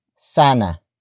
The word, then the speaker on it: sana: word is sana sana sana sana is spelled as s a n a.